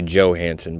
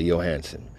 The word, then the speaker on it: Johansson: Listeners, everybody wants to say Johansson, but the J is pronounced with a Y, so it's Scarlett Johansson.